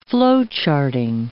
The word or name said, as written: flowcharting